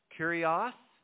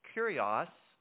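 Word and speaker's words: kurios (κύριος): If you want to do a deep dive Greek study on the word Lord in the New Testament, kurios is the, you almost hear the English word curious. It's pronounced similarly, but kurios, and it means Lord.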